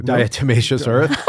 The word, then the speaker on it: diatomaceous earth: Deficit earth? – Desecrated. – Diatomaceous earth.